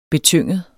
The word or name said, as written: betynget